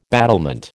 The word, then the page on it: battlement: American English